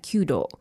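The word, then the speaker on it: kudo (弓道): You pronounce that kudo.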